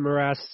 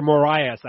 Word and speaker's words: Moraes: Marlon Moraes, or Moraes, actually, if you want to say it like us North Americans.